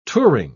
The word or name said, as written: touring